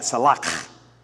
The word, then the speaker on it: Salach: And you got to say it like that, Salach, because that's Hebrew, right? Kind of like you've got something stuck in your throat, all right?